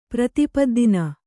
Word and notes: ಪ್ರತಿಪದ್ದಿನ: Kannada

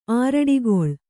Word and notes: ಆರಡಿಗೊಳ್: kannada

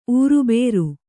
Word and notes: ಊರುಬೇರು: ūrubēru

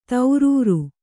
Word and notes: ಧೀರುರೆ: kannada

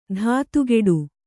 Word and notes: ಧಾತುಗೆಡು: dhātugeḍu

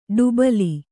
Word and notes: ಡುಬಲಿ: kannada

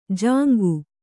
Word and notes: ಜಾಂಗು: Kannada

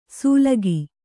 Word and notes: ಸೂಲಗಿ: kannada

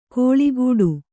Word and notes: ಕೋಳಿಗೂಡು: kōḷigūḍu

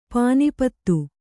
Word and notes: ಪಾನಿಪತ್ತು: pānipattu

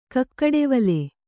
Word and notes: ಕಕ್ಕಡೆವಲೆ: Kannada

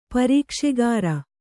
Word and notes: ಪ್ರೇಕ್ಷಾಗಾರ: Kannada

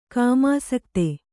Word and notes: ಕಾಮಾಸಕ್ತೆ: kāmāsakte

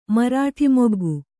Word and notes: ಮರಾಟಿಮೊಗ್ಗು: marāṭi moggu

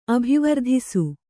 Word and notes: ಅಭಿವರ್ಧಿಸು: abhivardhisu

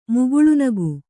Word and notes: ಮುಗುಳ್ನಗು: muguḷnagu

